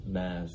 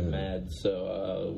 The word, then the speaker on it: Mads: Because I've been saying Mads Mikkelsen, and I think it's Mass. – Ooh. – Yeah, because I think the D is silent. I think it might be like a... He's Danish, right? – Correct. – I think it might be a Danish thing with the D being silent, because I noticed that Hugh Dancy and Brian Fuller both called him Mass.